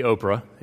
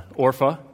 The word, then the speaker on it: Orpah: That's not supposed to be Oprah. It is Orpah. That's the way it's spelled.